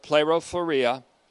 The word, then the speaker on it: plerophoria: The word for full assurance is this word, plerophoria, and that's a long E, plerophoria Thoria in Hebrews 6:11, which is our focus verse today, largely speaking anyways.